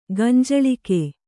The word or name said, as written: ಗಂಜಳಿಕೆ